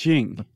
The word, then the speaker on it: Qing: I think it's, how do you spell it? – Jing, Q, Q-I-N-G. – Q-I-N-G. – Wait, the Jing. I think it's Jing.